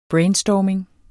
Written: brainstorming

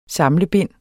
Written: samlebind

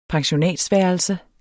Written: pensionat

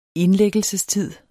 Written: indlæggelsestid

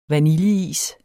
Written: vaniljeis

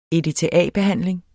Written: EDTA-behandling

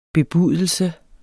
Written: bebudelse

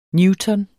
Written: newton

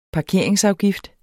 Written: parkeringsafgift

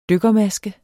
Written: dykkermaske

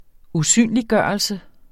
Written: SYNET

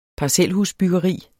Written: parcelhusbyggeri

